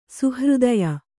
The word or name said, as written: ಸುಹೃದಯ